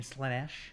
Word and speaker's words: Slaanesh: You mean Slaanesh? – Slaanesh. – Is that how you say it? – Yeah, that's you got to say it like that.